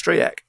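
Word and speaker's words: Stryjek: Well, I was saying Strijcek on Saturday. I've been told it was Strijcek. Then I had a tweet from someone whose wife is Polish, who was going apoplectic with my pronunciation, and says in Polish it's Strijek.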